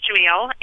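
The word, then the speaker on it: Jameel: Well, my middle name is Jamil, and it's an Arabic name.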